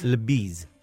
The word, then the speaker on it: la bise: how do you pronounce this? La bise? – La bise. Bise.